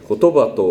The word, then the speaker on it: 言: この聖書箇所はちょっと読むと抽象的、哲学的で分かりにくい部分ですが、特に馴染みのないのが、ここにある言と書いて言葉と読む部分ではないでしょうか。この言、言葉と書いてある言葉はギリシャ語ではロゴスという言葉で、大変深い意味を含んでおります。つまりギリシャ語では言葉を表すとともに、